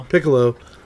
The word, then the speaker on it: Piccolo: Piccolo takes Gohan and is going to train him, and Gohan freaks out and like destroys a mountain or something.